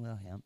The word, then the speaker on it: Wilhelm: Our guest for today is Pastor Jason Wilhelm.